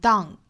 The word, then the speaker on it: done: 那这句话还有一个要注意的词就是 done, D-O-N-E, 很多同学会把它读成 done, 很像汉语里面这个当什么什么来了的当，那要注意里面的元音呢是 a, da, 再加 done.